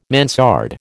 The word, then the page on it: mansard: American English